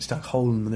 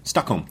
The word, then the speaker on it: Stockholm: You're pronouncing it right. It's Stockholm. There's an L there. – Yeah, Stockholm.